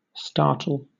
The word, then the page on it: startle: British English